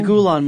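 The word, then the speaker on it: Gülen: The Gulen School? – The Gulen Movement.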